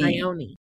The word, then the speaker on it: Ione: How do you pronounce, just having read it, her cousin's name? – Ione. – You're very close. Ione. It was Ione. Okay. How is it spelled? – It's just I-O-N-E.